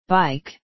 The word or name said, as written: bike